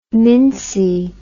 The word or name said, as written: mincy